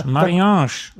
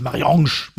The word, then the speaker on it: Marianne: Marianne. – Ja, im Buch heißt sie Marianne, nicht Marianne.